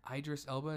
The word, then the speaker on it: Idris Elba: Idris Elba, is that how you pronounce his name? – Yeah.